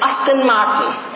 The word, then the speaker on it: Aston Martin: I said, excuse me, it's Aston Martin.